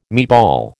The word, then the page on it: meatball: American English